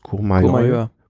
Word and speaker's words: Courmayeur: Courmayeur.